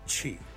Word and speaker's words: chi: Chi is pronounced chi, as in cheese.